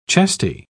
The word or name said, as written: chesty